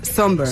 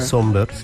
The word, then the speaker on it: Somber: Pues mira, yo busqué cómo se pronunciaba por si acaso, pero efectivamente se pronuncia Somber. – Somber, exacto.